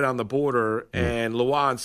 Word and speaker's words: Lewan: You hear about my boy Taylor Lewan. – Lewan. – Right, whatever. Lewan. – I'm just telling you how you say his name.